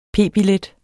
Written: p-billet

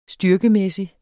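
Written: STYRKEMÆSSIG

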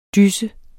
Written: DYSSET

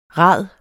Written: rad

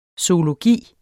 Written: zoologi

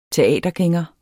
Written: teatergænger

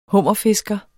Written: hummerfisker

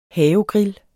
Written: havegrill

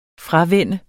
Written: fravænne

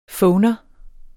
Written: phoner

